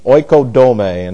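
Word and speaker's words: Oikodome (οἰκοδομή): O-D-O, and then we have M-E. Oikodome. This is O-I-K-O-D-O-M-E. Oikodome, and that E is pronounced as a long A.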